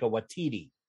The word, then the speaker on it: Waititi: He's played by Taika Waititi, and that's how you pronounce it.